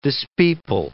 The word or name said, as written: dispeople